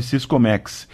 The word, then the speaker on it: Siscomex: Anote aí como se escreve Siscomex. S-I-S-C-O-M-E-X.